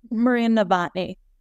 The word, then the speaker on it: Maria Novotny: Maria Novotny.